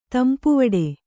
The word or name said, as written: ತಂಪುವಡೆ